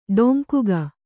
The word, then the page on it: ಡೊಂಕುಗ: ḍoŋkuga